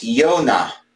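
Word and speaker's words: Yonah (יונה): And it's pronounced Yonah. And it means dove.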